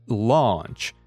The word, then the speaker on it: launch: One is launch. It sounds like lunch, but lunch is something you eat, and launch is when you start a project, when you start selling it in the market.